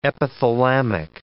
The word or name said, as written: epithalamic